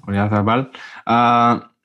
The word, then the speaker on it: Oyarzabal: My final pick as the attacker is Oyarzabal. Is that how you say it? – Oyarzabal.